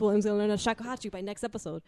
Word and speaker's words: shakuhachi: Shakuhachi.